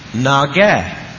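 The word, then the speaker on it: Naga (נגע): Naga, N-A-G-A, Naga.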